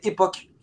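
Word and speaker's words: Epoch: Et c'était Époque. Ça s'écrit Époche.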